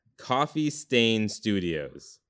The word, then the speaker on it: Coffee Stain Studios: Coffin. Wait, I said that wrong. Coffee Stain Studios.